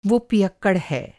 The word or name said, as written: पियक्कड़